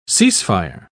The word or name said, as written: cease-fire